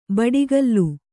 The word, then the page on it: ಬಡಿಗಲ್ಲು: baḍigallu